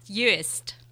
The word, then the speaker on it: Uist: It's spelled UIST, and it says here, Fiona, rhymes with tattooists. – Tattooist. Uist.